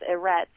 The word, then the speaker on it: Eretz: The Shamayim is how you say heaven, and the earth, or the Eretz, how you say earth in Hebrew is Eretz.